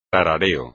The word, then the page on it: tarareo: espagnol